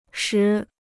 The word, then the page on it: 时: shí